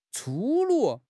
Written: 粗鲁

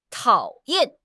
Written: tao3 yan4

